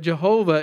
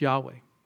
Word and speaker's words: Yahweh: But Jehovah is not the proper pronunciation. It's Yahweh, which kind of is funny because you think of this cult, Jehovah's Witnesses.